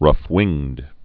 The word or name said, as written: rough-winged swallow